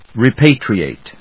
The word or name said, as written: repatriate